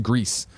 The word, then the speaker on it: Gris: Chris says, according to the developers, AMA, Gris is pronounced Greece. Well, they should have spelled it Greece, because then stupid people like me call it Gris.